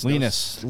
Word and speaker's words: Linus: It's Linus. – Linus Linus. – Rhymes with meanest.